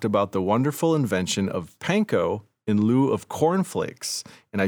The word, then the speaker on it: panko: The correct pronunciation of this Japanese word is panko.